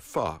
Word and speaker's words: pho: Well, there's a soup, a wonderful soup called pho, which rhymes with duh. Uh-huh. And it's spelled P-H-O, which for an English speaker seems odd.